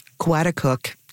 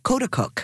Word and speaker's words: Coaticook: And when I first moved there, I called Coaticook, Coaticook. – Oh, that's a common one. Yep. – Yeah, and they let me call it that for 1 month before there was a group that came up to me and said, we don't call it that.